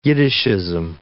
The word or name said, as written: Yiddishism